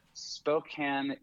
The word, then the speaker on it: Spokane: I've never been to Spokane. I spent some time in Seattle. – Right. – But Spokane it'll be a first time for me. And I'm glad that I now know how to say the name of the city. – Yeah, correctly.